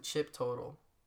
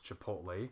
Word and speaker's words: Chipotle: I'm imagining Chip Total. – Something like that. Not quite. We got the first part of it right, but we didn't realize it was supposed to be pronounced Chipotle.